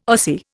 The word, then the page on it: Ossie: Indian